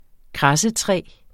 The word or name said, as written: kradsetræ